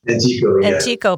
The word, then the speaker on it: Antico: Antico, yeah.